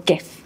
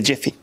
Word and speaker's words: GIF: GIF or GIF? – It is a GIF. – A GIF. – Yeah. A Jiffy.